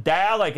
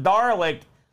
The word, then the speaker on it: Dalek: We looked at Xena, Warrior Princess, for the PlayStation, and Dalek Attack, and I've been told I can pronounce it Darlek, despite the spelling. So Darlik it is.